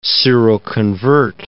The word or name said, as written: seroconvert